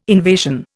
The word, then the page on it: invasion: Indian